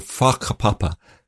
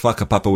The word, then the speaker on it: whakapapa: Like in Aotearoa, in New Zealand, in Maori culture, there's this concept called whakapapa, spelt with a WH, whakapapa, which is their word for lineage or genealogy.